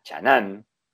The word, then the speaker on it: Chang'an: Es un gran proyecto bastante interesante que tiene la marca porque vos sabés que lo hizo junto con la marca china Chang'an, que todos sabemos que se pronuncia Chanan, Chanán, Chanan, siempre pensé en Jimán y listo, te sale perfecto.